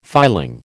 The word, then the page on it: filing: American English